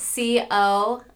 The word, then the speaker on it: Collier: C-O-L-L-I-E-R dot Bianca, B-I-A-N-C-A.